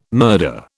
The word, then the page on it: murder: British English